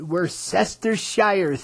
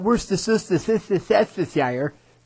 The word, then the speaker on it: Worcestershire: Yes, that's how you pronounce it, Worcestershire sauce. Not Worcestershire.